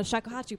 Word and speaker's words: shakuhachi: Shakuhachi.